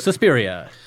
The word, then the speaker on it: Suspiria: It's Suspiria, right? That's how you pronounce it? – Suspiria. Dario Argento's Suspiria.